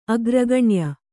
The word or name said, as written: ಅಗ್ರಗಣ್ಯೆ